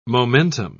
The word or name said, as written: momentum